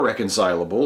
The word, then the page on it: reconcilable: American English